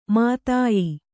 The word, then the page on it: ಮಧ್ಯ: madhya